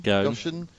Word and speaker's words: Ghosn: Charles, bloke I can't pronounce his surname, Ghosn? – Ghosn. – Gosh.